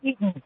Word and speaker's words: Dunedin: Dunedin, Florida. Am I saying that right? Watching Free Speech TV? – Dunedin, Florida.